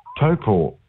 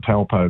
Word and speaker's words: Taupo: So then we're going back to Hamilton, which is a great place, and then we're going down to Lake Taupo. Some people call it Taupo, but it's the New Zealand pronunciation is Taupo.